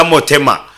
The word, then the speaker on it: motema: motema